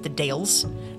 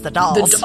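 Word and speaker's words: The Dalles: But it's not pronounced the Dales, it's the Dolls. The Dolls. – But the Dales sounds correct. – It's spelled D-A-L-L-E-S. So my brain was like, yep, that's the Dales. But it's actually pronounced the Dolls.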